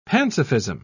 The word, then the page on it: pansophism: British English